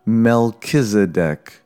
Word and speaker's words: Melchizedek: So in case you are reading the epistle in church in the future, we pronounce it Melchizedek. Melchizedek.